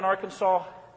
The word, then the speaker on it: Arkansas: They have a law that if you live there, you have to pronounce the word Arkansas correctly. So don't call it Arkansas, okay? Or Arkansas. And I'm used to that because I lived in Illinois, and how do people often pronounce Illinois? Illinois. Okay, the S is silent, as it is in Arkansas. There's no S at the end that you hear.